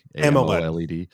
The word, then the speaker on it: AMOLED: AMOLED.